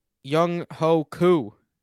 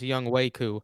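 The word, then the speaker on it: Younghoe Koo: Young Ho Koo. – Really? – Actually pronounced Young Wei Koo.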